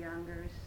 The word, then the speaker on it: Younger: I am taping an interview on November 15, 1983 in Mary Lee Younger's living room.